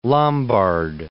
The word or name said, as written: Peter Lombard